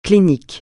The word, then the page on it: clinique: francais